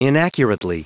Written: inaccurately

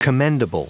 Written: commendable

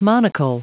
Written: monocle

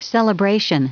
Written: celebration

